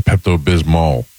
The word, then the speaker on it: Pepto-Bismol: Pepto-Bismo? – I thought it was Pepto-Bismol. – Yeah. – There is an L on the end. – Oh, there is. – Pronounce the L. I always pronounce it Pepto-Bismol.